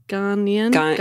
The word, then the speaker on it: Ghanaian: Ghanaian.